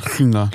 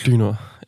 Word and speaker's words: Hlynur: Den ene, den første kortfilm, vi skal snakke om, det er en kortfilm fra 2022, der hedder Nest, af islandske instruktør Lynor Palmerson. Wow. Som, Eller Lynur. Lynur, ja. Hvis man skulle forsøge at udtale islandsk, det skal man ikke.